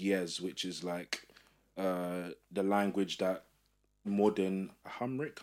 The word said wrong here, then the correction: Amharic: I'm butchering that. – Hamric, is, is based off. This is Ethiopian language, essentially. Amharic. That's it, guys. Amharic.